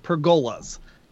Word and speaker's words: pergola: I call them pergolas because that's how I thought you pronounce it. And it's stuck in my head. I remember that. So I always have to like stop and go pergola, not a pergola. It's a pergola.